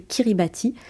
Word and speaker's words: Kiribati: Alors ça s'écrit Kiribati, mais on le prononce Kiribati.